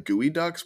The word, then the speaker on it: geoduck: No, I think they're called like gooey ducks, but it's spelled like, it's not at all spelled that way.